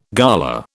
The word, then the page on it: gala: British English